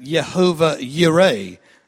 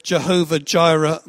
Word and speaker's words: Jehovah Jireh: Some people would pronounce it Jehovah Yireh. I don't know which is right. I know the song used to go, Jehovah Jireh, my provider. So that's kind of, you know, of course that's how you say it.